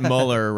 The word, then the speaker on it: Mueller: Just although my note is that Mueller rhymes with Crowler, which is a kind of donut, and that's what I would have said.